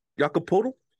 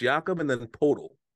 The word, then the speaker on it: Jakob Poeltl: Jakob Poeltl? It's Jakob and then Poeltl.